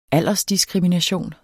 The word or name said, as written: aldersdiskrimination